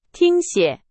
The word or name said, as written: 听写